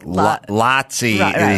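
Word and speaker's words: Laci: L-A-C-I-E. – Well, that's how he would pronounce. – Oh, like Lotsy is spelled Lacey. – That makes sense, yes. L-A-C-I-E.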